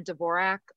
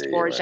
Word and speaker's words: Dvorak: But anyway, everybody always called her Dvorak. And that did become the common pronunciation and what she went by. And, you know, that quote, which is, I think it's in a book of quotations about her saying her name was pronounced Dvorak.